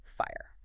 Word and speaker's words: FHIR: We're talking about HL7's Fast Healthcare Interoperability Resources, FHIR, pronounced fire.